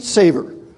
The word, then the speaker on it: savor: three of them produce something called sweet savior. Okay? Sweet savior. Oh, not savior. Sweet. I'm saying it wrong. Maybe I should look and see if I've got it written. Yeah, sweet savor.